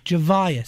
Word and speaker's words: Zhavia: No real Z. Zhavia. Say it. – Zhavia. – Zhavia. – I know how to say it. It's Zhavia. I don't know if you do. I think you're saying it a little wrong.